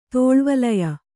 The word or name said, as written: ತೋಳ್ವಲಯ